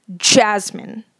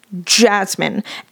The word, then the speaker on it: Jasmine: I will put way too much of an emphasis on the J. Like, I will be like, Jasmine. Jasmine.